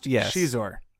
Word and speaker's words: Xizor: That's pronounced Xizor. – Yes.